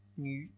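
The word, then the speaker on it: Nü (女): La V en chino es una vocal. Y es la U francesa. Nü.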